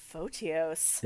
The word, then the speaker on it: Photios: Photios. – It's spelled Photios. – Photios? – Yep.